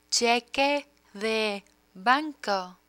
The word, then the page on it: cheque de banco: spanish